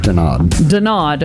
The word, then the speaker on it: Denaud: Dinaud. D-E-N-A-U-D.